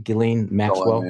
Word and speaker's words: Ghislaine Maxwell: The photoshopped picture of imposing his head over Jeffrey Epstein's, sitting on an airplane with Ghislaine, is that how you pronounce it? Ghislaine Maxwell? – Oh, yeah, And she's leaning over, massaging his foot, and he's drinking something.